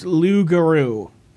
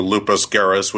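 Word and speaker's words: Loups=Garous: Which is apparently pronounced Lugaru. – Okay. – Which, to me, sounds like a Mazda dealer. – As opposed to Lupus Garris, which sounded like a disease.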